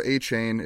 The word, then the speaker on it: Achane: Devon Achane, Davon Achane, he sent a whole big thing yesterday talking about the pronunciation, but it's no longer Achane, it's like Achane.